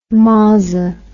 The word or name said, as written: mozzer